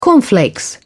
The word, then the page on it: cornflakes: British English